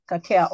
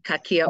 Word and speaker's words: Kakia: Kakia Oils. – Yes, Kakia Oils.